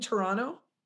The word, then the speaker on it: Toronto: But I know we're going to, don't you all live in Toronto? – Yeah. – Did I say it right? Did I say it like a Canadian? – Yeah, yes.